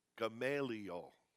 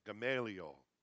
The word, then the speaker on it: Gamaliel: Gamaliel. That's how you say it. Gamaliel.